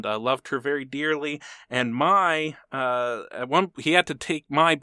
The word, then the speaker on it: Maj: It's spelled M-A-J, but it's pronounced Maj, and they get married in 1954, and they would be married for the rest of their life, and he never...